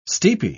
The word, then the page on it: steepy: British English